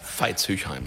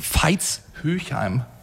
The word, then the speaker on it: Veitshöchheim: Und Feichtshöchsheim, wenn ich es jetzt richtig ausgesprochen habe. – Veitshöchheim. – Veitshöchheim.